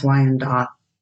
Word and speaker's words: Wyandotte: Am I saying that right? Wyandotte. – I'm pretty sure it's Wyandotte.